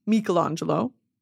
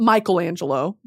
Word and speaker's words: Michelangelo: Michelangelo, not Michelangelo, okay? That's going to be the American pronunciation. Michelangelo. We're not doing that.